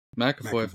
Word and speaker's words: McAvoy: McAvoy.